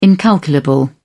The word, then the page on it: incalculable: British English